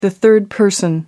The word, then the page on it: third person: American English